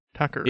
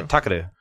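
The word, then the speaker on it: Takeru: Takaru is like, is it Takaru? Takaru? Takaru. – Takaru? – Probably. – Yes. – It's T-A-K-E-R-U. Takaru. – Yeah, Takaru.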